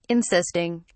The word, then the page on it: insisting: British English